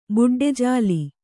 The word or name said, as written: ಬಡ್ಡೆಜಾಲಿ